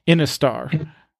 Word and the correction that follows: Intisar: Intisar. – There we go.